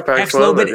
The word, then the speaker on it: Paxlovid: I am loaded with Paxlovid. Is that how you pronounce it? Paxlovid. – Oh yeah, Paxlovid yeah.